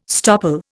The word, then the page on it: stopple: Indian